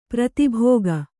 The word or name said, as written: ಪ್ರತಿಭೋಗ